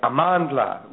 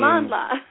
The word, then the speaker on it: Amandla: Yeah, well, you have to say it with a lot of energy. So you would, okay. – you would say Amandla, which means... – Amandla.